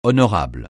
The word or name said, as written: honorable